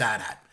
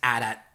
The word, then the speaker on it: AT-AT: And he responded to me and he said, no, it's AT-AT. But he said that the reason why it gets confused is, you know, the chicken walkers, like the two-legged ones? – The ATST? – Yeah, that's pronounced ATST. So right. Then people get thrown in, but the other one is AT-AT, 100%.